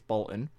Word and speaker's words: Bolton: Anyone in Bolton pronounces Bolton without the T and it's Bolton.